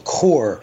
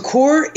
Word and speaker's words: coir: But there is something out there that is a peat moss substitute called Core. – C-O-I-R. – How do you spell it? C-O-R? – C-O-I-R. – C-O-I-R. – So Core is a byproduct of the coconut industry.